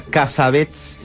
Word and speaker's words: Cassavetes: ¿Cómo se pronuncia Cassavetes en Estados Unidos? – Cassavetes. – Exactamente.